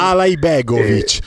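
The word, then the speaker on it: Alajbegović: Alaibegovic, ma tu Alaibegovic, fai Alaibegovic. Ala J, così Ala con la J della Juve, siamo a posto.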